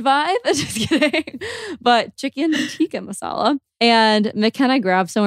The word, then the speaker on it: tikka: Tikka masala? I think it's tikka. – Yeah. – I always say chicken tiki and I know that's wrong every single time.